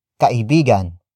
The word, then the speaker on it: kaibigan: It is usually followed by a role, like the next word, kaibigan.